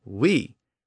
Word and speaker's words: oui: And then it lost the O sound at the beginning, and it just became we. But it retained that older spelling, O-U-I. That O at the beginning is a holdover from the original Latin word hoc, H-O-C.